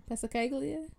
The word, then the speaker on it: Passacaglia: Passacaglia. I don't pronounce the G. The Halvorsen Passacaglia. Um, Passacalia.